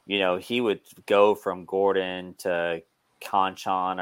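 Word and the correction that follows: Konchar: or I can't even Conchar.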